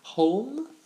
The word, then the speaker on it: Holm: C'est le personnage du robot magnifiquement joué par Ian Holm, In Holm, Holm, comme Sherlock Holmes.